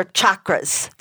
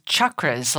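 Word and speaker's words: chakra: Is it chakras or chakras? The proper way to pronounce it is chakras, like the hard CH, like church.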